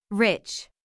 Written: rich

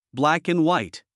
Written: black and white

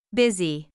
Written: busy

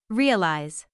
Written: realize

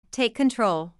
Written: take control

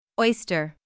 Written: oyster

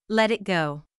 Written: let it go

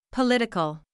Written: political

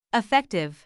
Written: effective

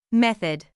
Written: method

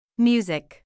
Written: music

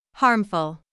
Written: harmful

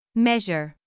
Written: measure

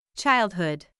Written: childhood

